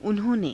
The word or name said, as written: unho~ne